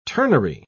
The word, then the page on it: ternary: British English